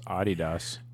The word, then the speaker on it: Adidas: Like, and if we can market, I mean, like, yeah, like Ninja, this dude's like Adidas is like super, like, I don't know. I think Adidas is like kind of snobby and stuff, but like, it's pronounced Adidas. – Adidas. Oh, right.